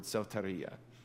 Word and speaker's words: soteria: It said it comes from the Greek word soteria. You say soteria? Soteria. Soteria. Roll your R. Come on, you got this.